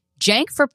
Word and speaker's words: Cenk: and it's like Cenk, like Jenkins, and I was like Stephen Jenkins of Third Eye Blind.